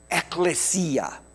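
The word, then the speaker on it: ekklesia (ἐκκλησία): it's actually pointing back to a Greek word that is pronounced ekklesia. Ekklesia literally means those called out to an assembly.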